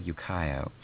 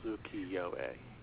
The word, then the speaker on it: ukiyo-e: I, you know, I always said ukiyo. So I'm glad you told me before I embarrassed myself publicly. – Yeah, it's four syllables, ukiyo-e.